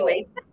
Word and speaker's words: Wais: So first of all, pronounce your last name for me. Is it Wais? Am I saying it right? – Yes, it's Wais. Yes, it's okay. Vicki Wais.